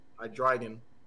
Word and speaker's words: Hydreigon: Hydreigon. Yeah, that's how you actually pronounce it, believe it or not.